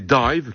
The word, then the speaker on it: DIIV: Voici un autre groupe tout à fait digne d'intérêt, le New-Yorkais Dive, qui s'écrit bizarrement D-I-I-V, qui est peut-être plus apprécié chez nous encore que dans son pays.